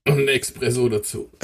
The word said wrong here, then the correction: Espresso: Ein Espresso.